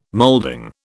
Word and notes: moulding: British English

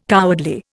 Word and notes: cowardly: Indian

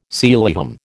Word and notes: Sealyham: American English

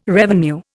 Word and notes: revenue: Indian

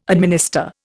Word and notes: administer: Indian

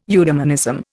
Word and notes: eudemonism: Indian

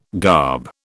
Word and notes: garb: British English

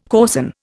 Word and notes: coarsen: Indian

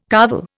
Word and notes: carvel: Indian

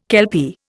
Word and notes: kelpie: Indian

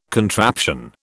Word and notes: contraption: British English

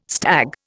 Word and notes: stag: Indian

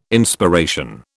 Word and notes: Inspiration: British English

